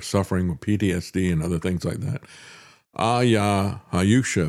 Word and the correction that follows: ayahuasca: if I'm pronouncing that correctly. Do you know how that's pronounced, Travis? Ayahuasca. – Ayahuasca? – Yep. – Is that it?